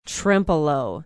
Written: trempealeau

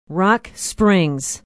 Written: Rock Springs

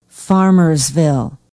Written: Farmersville

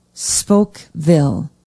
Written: spokeville

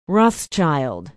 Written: Rothschild

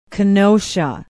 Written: kenosha

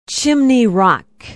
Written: Chimney Rock

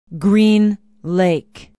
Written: Green Lake

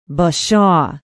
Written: bashaw

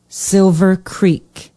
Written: Silver Creek

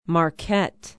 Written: Marquette

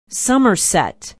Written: somerset

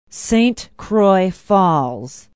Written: saint croix falls

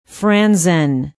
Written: Franzen

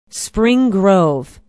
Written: spring grove

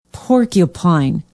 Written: porcupine

